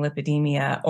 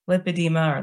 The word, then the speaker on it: lipedema: But if I go to a doctor's office and tell them I have lipedema, they think I'm saying lipedemia or lymphedema. So I always have to clarify, not lymphedema, not lipedemia, but lipedema or lipedema.